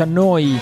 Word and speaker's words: Neu: Neu Radio si scrive Neu, si pronuncia Noi e vuol dire nuova, nuova emittente urbana da Bologna, come detto.